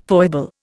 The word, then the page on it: foible: Indian